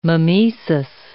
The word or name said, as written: mimesis